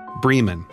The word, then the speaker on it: Bremen: But like many Georgia towns, Vienna, not Vienna, Cairo, not Cairo, it's pronounced a distinctly southern way, Bremen, in this case.